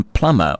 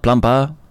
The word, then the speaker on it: plumber: Like, where does that B in plumber or plumbing come from? We don't pronounce it. It's not plumper. Plumping. No. So, I went and researched this. When you call for a plumber, there's obviously going to be a B in it, but you never pronounce it.